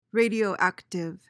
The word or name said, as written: radioactive